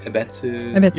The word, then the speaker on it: Ebetsu: And in the closest Sapporo, I might be saying it wrong, like Ebetsu. – Ebetsu.